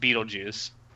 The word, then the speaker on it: Betelgeuse: I mean, that's he's named after the star, and that's how you pronounce the name of the star. – Oh, really? – Yeah. – Oh, okay. Wait, I don't believe you. – I mean, the way it's spelled in the subtitles that were on Crunchyroll, it's spelled exactly like the name of the star, and the name of the star is pronounced Beetlejuice.